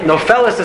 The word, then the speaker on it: nofeles: Yeah, nofeles is spelled in the Torah chaser. It's spelled without a vav. It's spelled nun, pei, lam, tav.